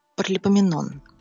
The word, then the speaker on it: Паралипоменон: Первая книга Паралипоменон, правильно говорить.